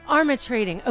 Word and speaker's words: Armatrading: So we just heard Jones, oh my gosh, Arms of Trading, Rush, did I say it correctly? No. Armis. – No way. – Armatrading. Okay.